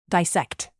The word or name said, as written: dissect